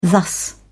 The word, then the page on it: thus: British English